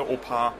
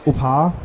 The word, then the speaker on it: OPAR: Habe ich das überhaupt richtig ausgesprochen? Oder OPAR? – Ja, also ich spreche es eigentlich immer in einem aus, also OPAR. – OPAR? Ja. Das klingt fast wie mein Opa.